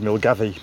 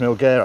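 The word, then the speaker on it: Milngavie: Oh well, it looks like it says Milgavi, but it's not. It's pronounced Milgar, I think.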